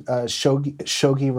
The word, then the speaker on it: Shogi: It's a game called Shogi, which is Japanese chess. For a Philadelphian, Shogi rhymes with hoagie.